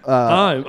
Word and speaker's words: Heim: It's Corey Uh, Heim. I'm pretty sure I pronounced that correctly.